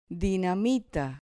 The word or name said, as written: dinamita